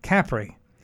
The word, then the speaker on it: Capri: The Italians actually pronounce it Capri,